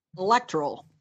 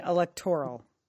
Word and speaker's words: electoral: I would say electoral. – Mm-hmm. Okay, for the record, the accent is on the second syllable, not the third. – Oh. – So, you did that correctly. It's electoral, not electoral.